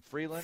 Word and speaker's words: Frelund: Frelund.